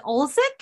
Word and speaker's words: Olczyk: Eddie, is it Olczyk? – Oh, that's very close. Yeah.